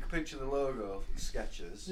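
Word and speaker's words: Skechers: There's no ease And it's spelled F-E-B-R-E-Z-E. – Febreze. – Febreze. It's the Febreze. Sketches has got no T in it. It's just, it's a ch.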